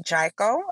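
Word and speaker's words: Jiko: There's a restaurant called Jaiko. I believe I'm saying that right. Yes. It's J-I-K-O.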